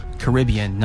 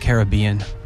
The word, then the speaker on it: Caribbean: Oh, and it's pronounced Caribbean, not Caribbean.